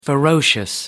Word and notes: ferocious: American English

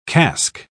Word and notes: casque: British English

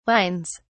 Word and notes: wines: British English

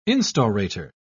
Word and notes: instaurator: British English

